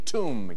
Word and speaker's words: tomb: What is B? – B in tomb, yes.